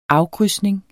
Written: afkrydsning

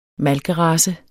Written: malkerace

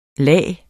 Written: lag

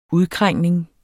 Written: udkrængning

